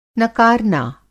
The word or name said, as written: नकारना